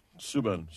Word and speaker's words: Subban: Is that how you say it? Subban? – PK Subban. – Subban. – Just the he goes both ways with it.